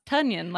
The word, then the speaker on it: Tonyan: I might be wrong, but I did see that somebody said, oh, it's pronounced Tonyan, like Funyan.